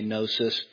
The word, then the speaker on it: gnosis: You actually pronounce the gamma nu together when you're saying Greek, but we don't in English, so we just say gnosis, and it means knowledge, right?